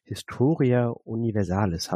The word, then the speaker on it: Historia Universalis: Er ähm, betreibt mit zwei Freunden einen Podcast, der heißt Historia Universalis.